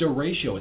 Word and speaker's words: derecho: It's called a derecho.